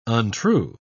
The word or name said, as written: untrue